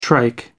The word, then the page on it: trike: American English